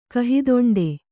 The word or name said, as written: ಕಹಿದೊಂಡೆ